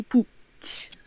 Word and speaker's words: Puig: Do you want me to tell you how to pronounce his name correctly? – Yes. – It's Ricky Pooch.